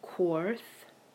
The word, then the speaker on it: Cweorth: The rune Jera, J-E-R-A. The rune Otila, O-T-H-I-L-A. A-R, which is spelled E-A-R, like ear. Quorth, C-W-E-O-R-T-H. – Can you say that again? – C-W-E-O-R-T-H. Quorth. Dagaz. D-A-G-A-Z. Hagalaz. H-A-G-A-L-A-Z. – Can you say it again? – Dagaz. – D-A-G-A-Z.